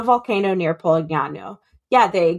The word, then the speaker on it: Polignano: Polangina. – It. No, it's they're in Polangina, I think. Let me see. It was on here. – Uh, Polignano. Polignano. That's where they are. Perfect pronunciation. Thank you.